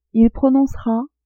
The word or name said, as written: il prononcera